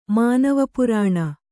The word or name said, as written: ಮಾನವಪುರಾಣ